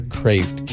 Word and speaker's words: Kreeft: And that's by Dr. Peter Kraft, K-R-E-E-F-T. I know it looks like Kreeft, but he pronounces it Kraft.